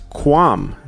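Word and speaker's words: Qualm: It's Quam, silent L.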